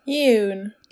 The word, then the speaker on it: Eun: Kan det vara Eun, Sweater? EUN Sweater av November Knits. – Jun. – Jun!